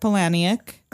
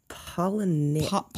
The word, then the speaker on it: Palahniuk: So this movie's based on a book by, and I don't know if I'm pronouncing this right because I've only read it, but Chuck Palahniuk. Is that right? – I think it's Palahniuk. – Palahniuk. Okay, Chuck Palahniuk.